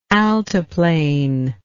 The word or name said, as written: altiplane